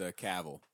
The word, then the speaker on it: Cavill: No, it's Cavill. – Cavill. – Rhymes with travel.